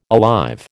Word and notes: alive: American English

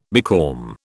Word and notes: becalm: British English

